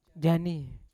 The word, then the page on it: janim: Yawa